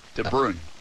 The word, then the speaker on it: De Bruyne: Did I say that right? – Belgium? – No. Bruyne. De Bruyne. – De Bruyne. – Bruyne? – Yeah. Close. Yeah.